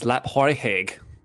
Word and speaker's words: Laphroaig: The correct pronunciation is Laphroaig.